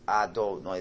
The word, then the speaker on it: Adonai: And when you say the Yud K Vav K name, which is pronounced Adonai the same way, that's God's transcendence.